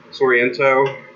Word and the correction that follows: Sorrentino: is... – Yes, Pablo Sorrentino. – Yes, sorry, I said his name wrong.